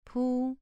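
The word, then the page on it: pu: tone 2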